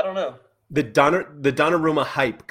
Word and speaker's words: Donnarumma: I'm just saying, and somebody in chat was just reminding us that it's Donnarumma, and you're absolutely right.